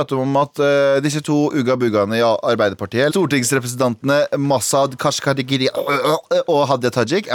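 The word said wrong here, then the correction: Gharahkhani: Masud Gorakhani.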